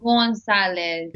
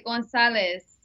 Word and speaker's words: Gonzalez: Well, in Spanish. – Well, you're Spanish, so why don't you say it like that? – Yeah, you would say it Gonzalez.